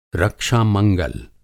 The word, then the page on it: रक्षा-मंगल: Hindi